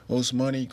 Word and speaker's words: Ousmane: Osmani Chroma. Osmani spelled O-U-S-M-A-N-E. Chroma spelled K-R-O-M-H.